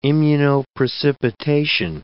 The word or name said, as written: immunoprecipitation